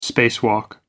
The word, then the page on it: spacewalk: American English